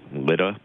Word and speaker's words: Lydda: How do you usually pronounce that? – Lydda. – Lydda. Okay, that's how I pronounced it too, so we'll stick with that then, as long as we're consistent, right? – Yep.